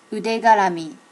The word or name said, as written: udegarami